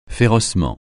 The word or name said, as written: férocement